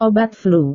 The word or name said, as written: obat flu